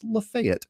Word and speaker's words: Lafayette: Side note, if you're in Nashville, that is pronounced Lafayette.